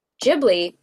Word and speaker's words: Ghibli: It's a Hayao Miyazaki film through Studio Ghibli, which actually, Ghibli being the Italian word for like ship, which is actually pronounced Ghibli, but Hayao didn't realize it was pronounced Ghibli, so it's pronounced Ghibli for the studio, even though he got it from the Italian word for ship.